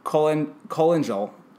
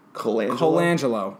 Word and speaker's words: Colangelo: Brian Colangelo. Wow, I messed that up. – Colangelo, jeez.